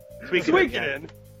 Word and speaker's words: Suikoden: Suikoden, how do you say that again? I apologize. – Suikoden should absolutely win.